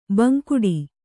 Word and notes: ಬಾಂಗಡಿ: Kannada

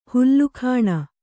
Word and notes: ಹುಲುಗಾಣ್: hulugāṇ

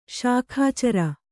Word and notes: ಶಾಖಾಚರ: śakhācara

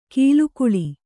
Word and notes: ಕಲ್ಲಿಗೂಳು: kalligūḷu

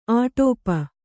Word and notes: ಅಡಪು: Kannada

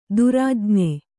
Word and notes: ದುರಾಜ್ಞೆ: Kannada

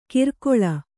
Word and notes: ಕಿರ್ಕೊಳ: Kannada

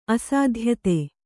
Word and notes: ಅಸಾಧ್ಯತೆ: Kannada

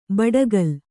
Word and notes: ಬಡಕಲು: baḍakalu

